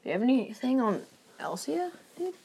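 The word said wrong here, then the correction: Elesa: Elisa? I said it wrong. It's Elisa.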